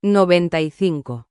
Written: 95

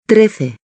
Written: 13

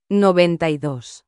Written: 92

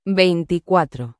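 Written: veinticuatro

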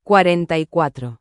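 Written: cuarenta y cuatro

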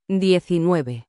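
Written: diecinueve

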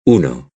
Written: uno

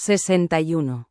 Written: 61

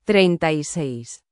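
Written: treinta y seis

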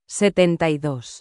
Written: 72

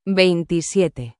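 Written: veintisiete

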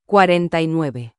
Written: 49

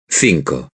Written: cinco

